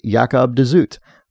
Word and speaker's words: de Zoet: The audiobook of The Thousand Autumns of Jacob de Zoet pronounces the name as Jacob de Zoet.